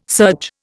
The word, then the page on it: surge: Indian